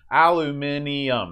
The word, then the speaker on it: aluminium: Aluminium, that's five syllables. – Yeah. – Aluminum, or in West Virginia, aluminum, that's three.